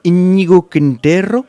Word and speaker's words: Íñigo Quintero: Est-ce que je prononce Íñigo Quintero? – C'est ça.